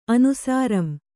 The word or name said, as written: ಅನುಸಾರಂ